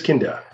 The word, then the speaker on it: Kinda: Is it Kinda or Kinda? – It is Kinda.